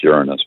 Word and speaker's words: Uranus: And it is pronounced Uranus, by the way.